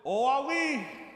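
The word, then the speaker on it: oawi (οὐαί): Now that word woe, again in the Greek, you can pronounce it, oawi, and it's an exclamation.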